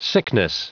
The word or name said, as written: sickness